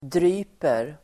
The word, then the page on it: dryper: dr'y:per